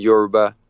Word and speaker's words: Yoruba: I mean, you've always spoken English, but you also speak Yoruba or Yoruba. – How how is that pronounced? – Yeah, Yoruba. Yoruba,